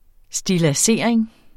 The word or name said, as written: stilladsering